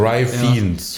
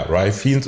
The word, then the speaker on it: Ralph Fiennes: Also Rai Fiennes. – Ich mache schon mal den Kenneth Branagh-Fehler. – Ja, Rai Fiennes oder wir sagen Mallory.